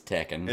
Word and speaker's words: Tekken: I've heard people pronounce it Tekken, which is weird to me. It's Tekken.